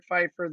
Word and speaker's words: Pfeiffer: And people are also welcome to look me up, Eric Pfeiffer. That's the last name is spelled just like Michelle Pfeiffer. That's the easiest way to explain it.